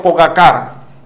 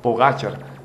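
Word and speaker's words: Pogačar: Hay gente que dice, por ejemplo, Pogacar. Y yo digo, Dios mío, Pogachar.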